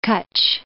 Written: Kutch

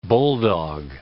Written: bulldog

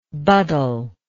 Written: buddle

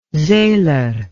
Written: seler

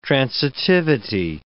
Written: transitivity

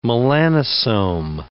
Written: melanosome

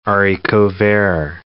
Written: haricot vert

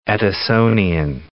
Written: Edisonian

